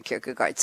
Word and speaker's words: Kierkegaard: And when I pushed the button that said click to hear the proper pronunciation, the way they pronounce Kierkegaard was something like Kierkegaard. I was like, ugh. And I couldn't make anything out of it, so I'm just saying Soren Kierkegaard. But here is the quote. I wasn't making fun. I'm just saying that it was such an eclectic pronunciation, so deep into the language that I couldn't make anything out of it. So for our sake, it's Soren Kierkegaard.